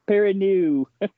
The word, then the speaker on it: Perrineau: Perrineau? – Perrineau.